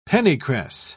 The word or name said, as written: pennycress